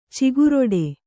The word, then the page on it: ಚಿಗುರಿಡು: kannada